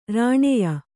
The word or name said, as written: ರಾಣೆಯ